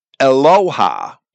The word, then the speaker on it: Eloah: the form of the word is the singular form Eloha, E-L-O-A-H, it's spelled in English, Eloha, which is used quite often of Yahweh throughout Scripture, but not as often as the plural of majesty, Elohim.